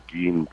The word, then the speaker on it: Gein: and somewhere along the line the MC got dropped, but they still maintained the Gein pronunciation. So McGean. – So Gein is correct.